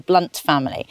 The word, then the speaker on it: Blount: And the pronunciation really is an individual thing. Someone Blounts or Blunts will swear that it's one way to pronounce it, and some will swear it's the other way.